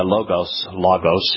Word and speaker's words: Logos: Logos is how they pronounce it, interactive software.